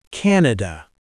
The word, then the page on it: Canada: American English